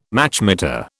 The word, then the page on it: Machmeter: British English